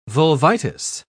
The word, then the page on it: vulvitis: British English